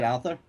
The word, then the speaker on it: Altherr: You know, Aaron Altherr, is that how you say it, Altherr? – Altherr.